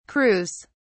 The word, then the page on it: crews: British English